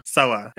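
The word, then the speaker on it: Sele: Sela. It is pronounced Sela, but if you want to remember it, it's sort of like Seal, and seals live in the blue ocean.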